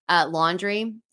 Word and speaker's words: Londre: And I found this brand called Laundry and it's spelled L-O-N-D-R-E, Laundry.